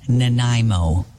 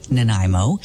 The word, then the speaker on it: Nanaimo: fair to say most of us on the West Coast know how to pronounce the name Nanaimo, a city on Vancouver Island, the destination for the Horseshoe Bay Ferry over to Departure Bay, Nanaimo.